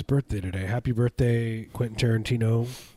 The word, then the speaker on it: Tarantino: Hey, Quentin Tarantino. That's how he would say. He would pronounce the T's.